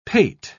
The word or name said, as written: pate